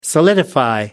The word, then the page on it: solidify: American English